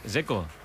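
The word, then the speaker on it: Džeko: DZ, ¿cómo se pronuncia? – Dzeko. Bruno Dzeko. Dice que es Dzeko.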